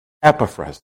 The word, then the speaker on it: Epaphras: And for my teachers, oh, you should say Epaphras. No, I checked it. In a lot of sources, Epaphras. So the emphasis is on the E.